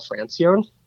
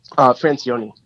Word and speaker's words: Francione: Francione. Francione, actually.